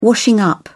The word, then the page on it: washing up: British English